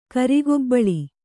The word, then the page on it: ಕರಗಬಳ: Kannada